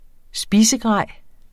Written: spisegrej

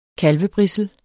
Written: kalvebrissel

